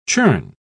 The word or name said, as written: churn